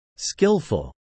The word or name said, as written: skillful